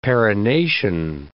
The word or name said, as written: perennation